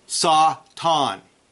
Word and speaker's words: Satan: Number one, as we noted, the most common word is Satan from the Hebrew Satan, pronounced Satan. Satan.